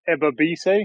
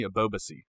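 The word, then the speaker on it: Ebobisse: But another standout player for San Jose was, I'm going to butcher the name, but is Ebobese, is that right? – Jeremy Ebobese. – That was nowhere near it.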